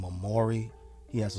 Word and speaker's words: Morray: So shout out to Maury. The song's called Quicksand. You spell his name. It's spelled M-O-R-R-A-Y, Maury.